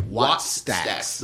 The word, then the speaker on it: Wattstax: Wattstax.